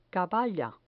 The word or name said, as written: caballa